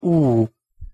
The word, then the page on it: u: hosszu; Hungarian